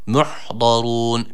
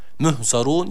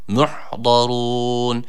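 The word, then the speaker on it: Muhdarun (مُحْضَرُونَ): Muhdarun. Birincisi h harfi, sakin olan h harfine dikkat edelim. Bunu h ile okumayalım. Muhzarun yanlış okuyuş olur. Muhdarun. İkincisi de dat harfi, yine burada kalın okunması gereken bir harf.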